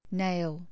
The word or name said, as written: nail